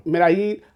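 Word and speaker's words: Medellín: Medellin. All right, cool. Somebody told me I think it was Medellin. They say that's how the two L's pronounced as J's.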